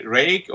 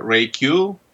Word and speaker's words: Reiq: Yeah, you can say Reiq or Reiq, but both are fine. I take both.